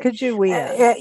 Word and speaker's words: Sacagawea: I think Lewis and Clark, I mean, they're in Sacagawea. Yeah, I was saying it right. – Sacagawea.